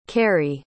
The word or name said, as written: kari